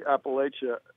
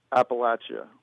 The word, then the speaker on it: Appalachia: I think the Mason-Dixon line, I think below the Mason-Dixon line, it's Appalachia, largely. People pronounce it that way. And I think above the Mason-Dixon line, it's Appalachia. We're very close to the Mason-Dixon line. It's an hour south of us. So we get both pronunciations here.